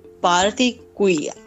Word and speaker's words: Barigui: Barigui. – Barigui. I don't know if I'm saying it right. – Let's say this is perfect.